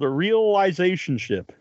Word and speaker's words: Realisationship: and it's called Realizationship. Is that how it's pronounced? – That's right, yes.